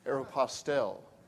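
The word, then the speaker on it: Aéropostale: By the way, did you know that that's how you pronounce it? Aeropostale.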